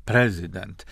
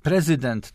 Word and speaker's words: prezydent: Prezydent to jest z tym akcentem na pierwszą sylabę, to jest po amerykańsku, po polsku prezydent.